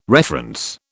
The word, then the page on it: reference: British English